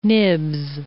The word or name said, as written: nibs